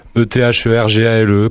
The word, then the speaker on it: Ethergale: Ethergale, comme l'éther et comme la gale.